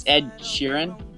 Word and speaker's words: Sheeran: It's Ed Sheeran. You're pronouncing it pretty weird.